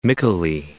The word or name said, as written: Mycale